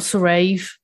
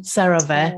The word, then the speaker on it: CeraVe: So I got Cerave, the lotion. CeraVe. Oh, wow. Sorry, I always say it wrong. CeraVe.